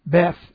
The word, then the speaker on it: Beth: The beta, Beth is the way it's pronounced, Beth, to swear by a person means to call upon Him as your God when taking an oath.